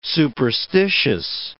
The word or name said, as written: superstitious